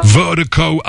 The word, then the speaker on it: VIRDIKO: Vertigo out of Dallas, Texas. Appreciate the love, appreciate the support. And Vertigo is spelled V-I-R-D-I-K-O. That's V-I-R-D-I-K-O. Pronounced Vertigo.